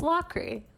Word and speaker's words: Lockery: It's Lockery. Like, you know, when you lock a key, but there's an R in there, so it's like Lockery.